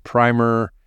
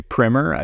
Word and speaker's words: PRIMR: Before, in case I embarrass myself anymore, did I pronounce everything correctly, including PRIMR, your name? – You did. You got it all right. I've heard some people say PRIMR. I think that's more like the British way.